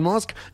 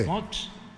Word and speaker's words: Musk: Musk. – No, no, menso, así no. – Haga de cuenta que va a decir mosca y le quita la A.